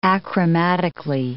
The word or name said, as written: achromatically